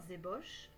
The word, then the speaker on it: ébauche: Par exemple, si je dois rédiger un rapport, je vais écrire plusieurs ébauches avant. Pensez à bien prononcer le son et au début. Ébauche.